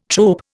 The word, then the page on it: trope: Indian